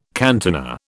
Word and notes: cantina: British English

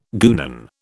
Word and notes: guenon: British English

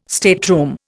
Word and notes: stateroom: Indian

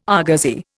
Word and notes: argosy: Indian

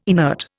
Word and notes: inert: Indian

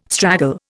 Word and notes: straggle: Indian

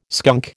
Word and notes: skunk: American English

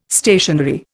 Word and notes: stationery: Indian

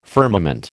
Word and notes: firmament: American English

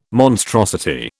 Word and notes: monstrosity: British English

